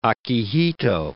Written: Akihito

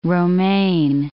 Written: romaine

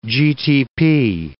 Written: GTP

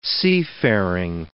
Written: seafaring

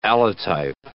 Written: allotype

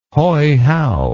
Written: Hoihow

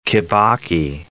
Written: Kibaki

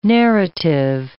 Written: narrative

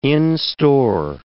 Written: in-store

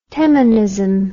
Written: Teminism

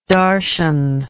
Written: darshan

